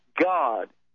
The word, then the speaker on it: Gad: But it's actually pronounced God. That troop is really a pagan deity by the name of, quote-unquote, G-A-D. It was pronounced G-A-W-D, which is the same thing for our G-O-D in English.